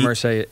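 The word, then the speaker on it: Irsay: Is it Ursay? How do you say this man's name? – Ursay. Jim Ursay.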